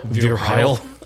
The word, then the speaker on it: Virel: I think that's what we've settled on how to pronounce Virel. V-I-R-E-L.